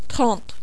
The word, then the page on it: 30: French